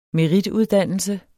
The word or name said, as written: merituddannelse